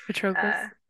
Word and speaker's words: Patroclus: Yeah, Patroclus.